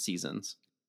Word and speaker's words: SZNZ: seasons. And so this one is from spring, and seasons is spelled S-Z-N-Z, just FYI.